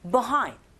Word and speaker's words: behind: 혀가 어디에도 닿지 않게끔 하시고 비하인드로 들리는 경우도 있지만 빈번하게 원어민의 입에서 나오는 소리는 behind, B라고 안 들리고 그냥 아주 간략하게 behind 이렇게 뒷부분에 강세 줘서 발음한다라는 거